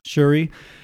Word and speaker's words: Shuri: Like Suri or something? The Black Panther's little sister, right? – Shuri. If you pronounce the SH, yeah, it's Shuri.